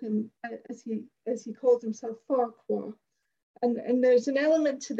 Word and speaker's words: Farquhar: We know up here, it, yeah, it should pronounce it "facker." Now, there's obviously reasons why they did this, because there are probably complaints going to Ofcom if they pronounce it the Scottish way.